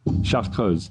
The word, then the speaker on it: Chartreuse: The French, they don't say the R, they say R. You know, the R? – Chartreuse. – Yeah, the R letter is like R. – Okay. – So that's what makes it a little bit weird to pronounce. – Did I get kind of close? – Yeah, yeah. Okay. And then, you know, Chartreuse. – The letter U for them, it's always pronounced as U. So when you combine it with other letters, it goes U's.